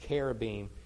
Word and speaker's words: cherubim: Now, I may have just thrown you off by the way I pronounce cherubim. In the Hebrew, that's a k and not a ch.